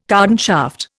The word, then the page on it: cardan shaft: Indian